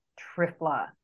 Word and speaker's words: Triphala: And so one of my favorite things is an Ayurvedic herb called Trifla, and that's spelled T-R-I-P-H-A-L-A.